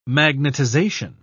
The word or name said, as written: magnetization